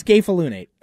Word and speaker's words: scapholunate: I literally looked up a video on how to pronounce it. – Great. – It's scapholunate.